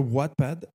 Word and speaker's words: Wattpad: Y Wattpad es, eh, se escribe W-A-T-T Pad, Wattpad.